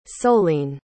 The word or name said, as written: solène